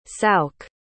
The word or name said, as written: souke